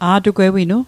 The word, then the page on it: a'tugwewinu: Mi'gmaq